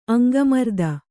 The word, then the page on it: ಅಂಗಮರ್ದನೆ: aŋgamardane